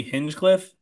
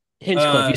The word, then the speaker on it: Hinchcliffe: Tony Hinchcliffe. – Exactly. Hinchcliffe.